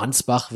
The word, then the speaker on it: Hansbach: Dann seine rechte Hand, dieser Sergeant Hansback, also Otto Hansbach, wie ich es auf Deutsch aussprechen kann, wird gespielt von Ernest Borgnine.